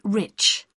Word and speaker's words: Rich: It's Mrs. Rich. – How do you spell that? – R-I-C-H.